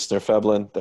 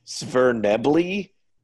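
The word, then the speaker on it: Svirfneblin: It is Snerfeblin. That is how we pronounce it. Thank you. – How do wrong people pronounce it? – Man, I was listening to a Dritz book on audio tape, and somebody called them Svernebly, and I was like, nah, that's not how you say that.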